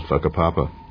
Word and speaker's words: Whakapapa: Mount Ruapehu, the name of the volcano is a Maori word, and in Maori, WH is pronounced as an F, so the name of the mountain is called Whakapapa.